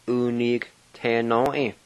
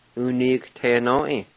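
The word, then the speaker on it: Uniquatenoi: And the Q-U-A, you just mainly pronounce the Q sound. And it's pronounced like this. U ni te no e.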